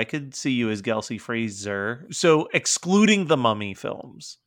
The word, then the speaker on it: Fraser: I think his name is pronounced Brendan Fraser. – Fraser. Sorry. Fraser. I trust you.